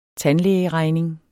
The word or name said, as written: tandlægeregning